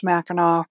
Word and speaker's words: Mackinac: But I just had to stop in the middle of it to point out that he is not pronouncing Mackinac correctly. Any Michigander will tell you that hearing Mackinac and Michilimackinac is like nails on a chalkboard. It's all pronounced Mackinac, regardless of whether it's spelled with a C or a W at the end. Mackinac City versus Mackinac Bridge and Island, etc.